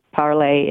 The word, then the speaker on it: parler: I lived in France for 3 years, so I parlay, P-A-R-L-E-R. It is spelled, or is pronounced, parlay in French, parlez-vous français.